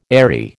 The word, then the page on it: eyrie: American English